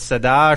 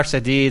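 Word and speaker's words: Tsade: The next stanza, the Hebrew letter is Sadi, S-A-D, well, it's actually T-S-A-D-H-E, and you can write that. It's Sada or Sadi, that's, depending how you say it, and it's purity and truth of God's word.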